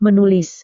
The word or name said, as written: menulis